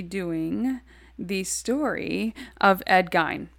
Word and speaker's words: Gein: Three different pronunciations. – We just had three different. So it's Gein.